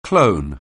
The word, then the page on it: clone: anglais